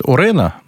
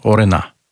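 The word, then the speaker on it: Orano: Ее все почему-то называют Орена, но Орена, ударение, последний слог.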